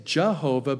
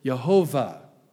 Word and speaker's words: Jehovah: Some say Jehovah, but that can't be because the Hebrews don't have the J sound. They would pronounce it Yehovah. If it was Jehovah, it'd be Yehovah.